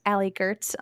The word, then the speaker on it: Allie Goertz: Yeah, so you could find me at Allie Gertz on all the things, and that's spelled A-L-L-I-E-G-O-E-R-T-Z.